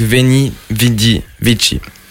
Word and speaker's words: Veni Vidi Vici: En avant-dernier, il y a Lacrime avec Veni Vidi Vici.